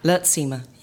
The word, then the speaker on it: Luurtsema: Perfectly. Luurtsema. Yeah. – Luurtsema. – It's Dutch.